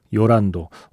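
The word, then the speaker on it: Llorando: 제일 먼저 들려드린 곡은 영화 머롤랜드 드라이브에서 요란도. 원래 발음은 스페인어 발음으로는 요란도인 걸로 저는 알고 있는데 노래할 때 계속 조란도라고 발음을 하길래 이게 요 혹은 조로 발음해도 되죠. 근데 저는 요란도라고 다시 말씀드리겠습니다.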